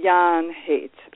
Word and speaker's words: Jahn Haight: It's Jahn Haight.